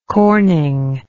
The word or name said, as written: Corning